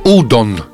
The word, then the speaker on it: udon: Nazywa się udon. Pisze się U-D-O, jak udo, z N na końcu.